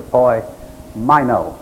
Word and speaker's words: poimaino: The other type of ruler is a poimaino. The A-I is like pronounced like a long I.